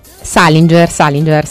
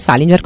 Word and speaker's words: Salinger: Salinger, sì, anch'io facevo questo errore all'inizio, lo volevo fare più straniero, invece è semplice, Salinger, così.